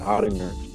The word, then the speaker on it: Ottinger: This is Brian Ottinger.